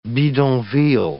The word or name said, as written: bidonville